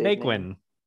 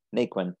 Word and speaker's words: Naquin: Tyler Naquin, I think that's how you say Naquin. – Naquin.